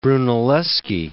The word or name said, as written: Brunelleschi